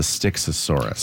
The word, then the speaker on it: Styxosaurus: I had to Google how to say it. It's a Styxosaurus.